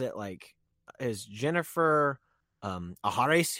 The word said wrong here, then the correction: Agares: here? And she goes, it's a Garris, and it's a white girl.